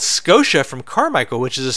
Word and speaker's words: Skeauxsha: The band name is Scotia. It's spelled S-K-E-A-U-X-S-H-A.